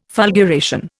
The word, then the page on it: fulguration: Indian